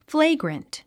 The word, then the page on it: flagrant: fléiɡrənt; フレイグラント